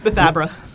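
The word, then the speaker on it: Bethabara: Did I say that name correctly? – Not quite. – Okay. So that's how I say it when I'm trying to spell it. – I'm from the Midwest. I just said it. – Bathabra. – Bathabra. Okay.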